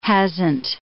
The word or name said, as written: hasn't